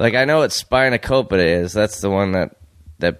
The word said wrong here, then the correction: spanakopita: Yeah, Isn't that how you say it, or no? – How'd you say it? – Spinacopita. – I think it's spanakopita.